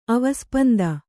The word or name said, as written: ಅವಸ್ಪಂದ